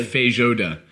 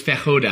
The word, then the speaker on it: Feijoada: No, it's actually... It's pronounced almost exactly as it's written. – Oh. – Feijoada. – Feijoada. – But I thought it was fejoda, and I was telling everyone on the shoot for like 2 months, and then... And this prop stylist came in and being like, you're an idiot. That isn't how it's pronounced.